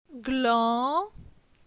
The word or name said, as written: Glan